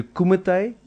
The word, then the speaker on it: kumite: Is it a kumite? – Yes.